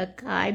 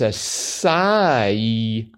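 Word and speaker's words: açaí: Acai is pronounced acai. Keep on keeping on. You guys are tireless. Thanks for what you do every single day, Randall. And he also included a YouTube video. We'll try and include that in the show notes that gives real-life examples of how you pronounce acai. – It's not a kai berry. – It's not a kai berry. It's a sai berry.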